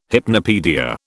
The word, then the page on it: hypnopaedia: British English